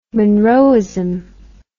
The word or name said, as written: monroeism